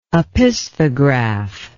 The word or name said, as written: opisthograph